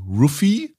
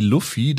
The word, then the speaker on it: Luffy: Ruffy, im Englischen Monkey D. Luffy. Da haben sie irgendwie versucht, das mit der Aussprache, weil es ja im Japanischen mit R und L so Probleme gibt, äh, das zu ändern. Ich glaube, das Korrektere ist, wie es in Deutschland gemacht wird, dass sie ihn Ruffy nennen.